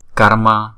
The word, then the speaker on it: karma: Karma, karma. Chữ K này chúng ta đọc là K. Karma, karma. Chúng ta phải đọc nghe chữ R nữa nha.